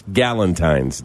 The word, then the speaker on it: Galentine's: Gal, G A L. Yeah. E N T I N E S. Galentine's Day instead of Valentine just the gals.